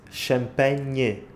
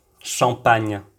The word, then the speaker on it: Champagne: E nesta palavra temos ainda outro som que é igual, mas este já se escreve de forma diferente. Champagne. Escrevemos com NH o som N e em francês com GN.